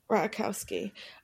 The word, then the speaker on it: Ratajkowski: So Emily Ratajkowski. Is that how you say it? I still don't know how to say her name. – Ratajkowski.